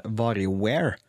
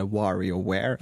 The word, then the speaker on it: WarioWare: en serie spill som heter Vario Ware eller WarioWare, eller hvordan du vil uttale det.